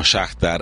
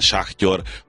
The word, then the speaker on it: Shakhtar: Ilyen tekintetben is ugye az Eindhoven, a Shakhtar ellen, Shakhtyor, hogyha nagyon szépen akarjuk kiejteni, ezekkel zárul, ugye, ami a labdarúgást illeti.